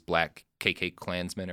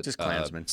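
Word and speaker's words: BlacKkKlansman: Black KK Klansmen, or however we're supposed to pronounce it. – Just Klansmen.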